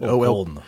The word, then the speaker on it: Olkhon: 45 islands, the largest of which, Olkhon Island, not sure I'm saying that right, but Olkhon. – Thank you.